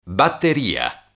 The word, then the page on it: batteria: Italian